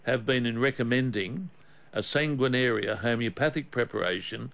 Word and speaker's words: sanguinaria: And one of those herbs is a herb called sanguinaria. I'll spell it for you. S-A-N-G-U-I-N-A-R-I-A, sanguinaria.